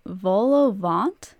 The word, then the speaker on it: vol-au-vent: And I discovered these things called, okay, I'm probably gonna butcher this because it's French, but it's a vol-au-vent. – I think you actually said that correctly. I think. I could be wrong.